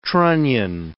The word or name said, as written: trunnion